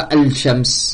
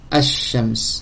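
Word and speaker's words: Ash-Shams (الشمس): On dit pas al-shams. On prononce pas le lem. On prononce directement. Le chat Ash-Shams.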